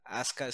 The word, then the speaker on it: Asuka: Asuka Azuka o Asuka como lo pronuncian ellos. El caso es de que llega, y Asuka está toda vergada, ahí en, el pinche hospital, güey.